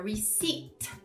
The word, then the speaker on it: receipt: Você vê que tem um P lá, mas você não fala receipt. Você ignora, ele fica mudo, fica receipt.